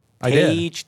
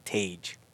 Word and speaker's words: Tage: Tage Thompson. – Dang, correct. Look at me, huh? – It's like Grange. – Right. – It's like Range with a T, Tage.